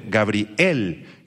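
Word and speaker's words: Gabriel (גבריאל): Subrayen la palabra hebrea para Gabriel, se escribe exactamente igual que en español, pero con acento en la E. Gabriel es en hebreo, Gabriel, que significa guerrero de Dios, guerrero de Dios u hombre de Dios.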